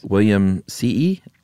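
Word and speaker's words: williamsii: William C.E. Now, we decided that two I's means you pronounce both, right? – You have to go E.E.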